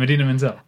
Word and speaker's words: Idina Menzel: Idina Menzel. No. – Adele Dazeem. – Yep. What's her real name? Idina Menzel. – You had it the first time. Idina Menzel.